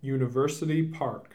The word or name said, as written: University Park